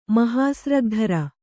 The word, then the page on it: ಮಹಾಸ್ರಗ್ಧರ: mahāsragdhara